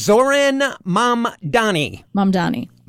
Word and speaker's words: Zoran Mamdani: Zoran Mamdani.